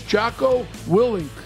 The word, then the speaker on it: Jocko Willink: His name is Jocko Willink.